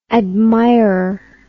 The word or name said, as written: admirer